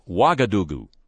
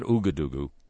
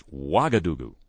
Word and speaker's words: Ouagadougou: While we're on the subject of me mispronouncing stuff, Ouagadougou, not Ouagadougou. 500,000 listeners told me last week after the broadcast, Ouagadougou.